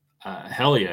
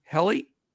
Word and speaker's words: Hjelle: And how do you pronounce this name? H-J-E-L-L-E. Any clue? – Helly, I think. – Helly? – Mm-hmm. – The J silent? Silent? – Silent, yeah.